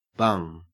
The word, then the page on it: bung: Australian English